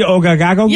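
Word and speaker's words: Ouagadougou: That's the name of the city, Ogagadougou.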